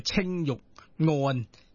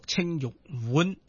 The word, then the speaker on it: 青玉案: 即系其实呢个系南宋嘅爱国词人辛弃疾所写嘅一首词，就嗱有两个读音嘅，一个叫青玉案。有啲人话要读青玉碗，其实嗰个原即系老师你点睇呢啲嘢？